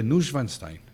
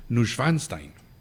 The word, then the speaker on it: Neuschwanstein: El castillo de Neuschwanstein, perdón, mal pronunciado, Neuschwanstein, y otros castillos en Europa no son otra cosa que un monumento al alma.